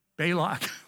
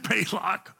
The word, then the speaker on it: Balak: Some among you who hold to the teaching of, I don't know how to pronounce, Balaam, teachings of Balaam, and Balak. Is that it? That pretty close? Balak. Okay. Balak. That pretty close? Okay. Well, not really. Oh, Balak.